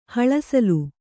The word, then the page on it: ಹಲುಸಲ್ಲೆ: Kannada